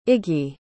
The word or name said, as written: Iggy